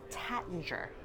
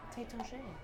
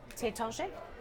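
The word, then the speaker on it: Taittinger: Americans, we tend to say Tattinger. And because most people don't realize the AI is there. – Yeah, but like French is hard to pronounce. – Tétanché.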